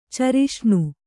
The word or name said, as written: ಚರಿಷ್ಣು